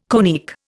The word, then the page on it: conic: Indian